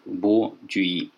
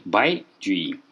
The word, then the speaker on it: Бо Цзюй-и: На самом деле, конечно, его китайцы читают Бай Джуи, но по-русски у нас еще с 19 века сложилась традиция двух поэтов, чье фамилия пишется иероглифом Бай, читать старым чтением Бо, которое, кстати, можно найти в словаре Палладия.